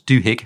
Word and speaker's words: Duhigg: And the other book that I really would recommend to anyone is The Power of Habit by Charles Duhigg. I think it's Duhigg. Never never said it out loud. It's, it's spelt Duhigg. D U H I double G.